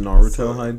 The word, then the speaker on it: Naruto: It's pronounced Naruto.